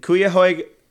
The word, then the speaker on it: Cuyahoga: On May 31st of 2019, there we go, in Cuyahoga. – How is it spelled? – C-U-Y-A-H-O-G-A.